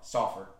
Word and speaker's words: Sulphur: It's pronounced like S-A-U-F-E-R. Sulphur.